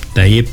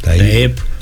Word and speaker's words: Ταΐπ: Μέσα στα άλλα είδαμε και τις φιλοφρονήσεις εκατέρωθεν ανάμεσα στον πρόεδρο των Ηνωμένων Πολιτειών και τον πρόεδρο Ταΐπ Ερντογάν, Ταΐπ Ερντογάν, όπως λέει και ο φίλος μας ο Μανώλης ο Κωστίδης. Έχω την πιο καλή προφορά αυτή. – Ταΐπ.